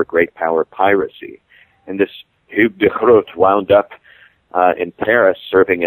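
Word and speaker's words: Hugo de Groot: But Grotius was known in his own time by his Dutch name, which is the rather more gutturally pronounced Hugh de Groot.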